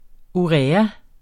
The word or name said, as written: urea